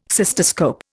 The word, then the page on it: cystoscope: Indian